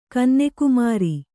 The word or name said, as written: ಕನ್ನೆಕುಮಾರಿ